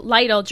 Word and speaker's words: Lidl: Lidl, L-I-D-L. There's a German grocery store. Lidl, yes.